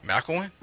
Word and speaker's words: McElwain: So that's what the Jim McEwen, who is the former coach of Florida, he's now at Central Michigan. – That's pronounced wrong. – How do you pronounce it? – Isn't it McElwain? – Maybe.